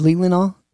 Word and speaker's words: Leelanau: Leland? – Leelanau? How do you say that? – Is it Leelanau? – Yeah.